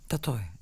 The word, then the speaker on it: Tatoi: So to see it being implemented in certain places here in Tatau, like, am I saying that right? – Tatoi. It's Tatoi.